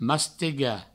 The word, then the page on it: mastegar: mastega